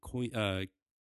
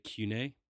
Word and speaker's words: Koine: By this point, it's called Koine Greek, is how a lot of people say it. Other people would pronounce it Kune.